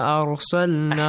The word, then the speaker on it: أَرْسَلْنَا: كَمَا أَرْسَلْنَا. – أحسنتم. كَمَا أَرْسَلْنَا. If you skip it, كَمَا أَرْسَلْنَا. Because the mad is جَائِز مُنْفَسِن. That's it. – Yes. – أَرْسَلْنَا. Ra must be pronounced heavily.